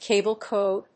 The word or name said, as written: cable code